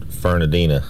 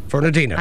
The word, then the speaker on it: Fernandina: Old shrimpers pronounce it Fernandina. – Fernandina.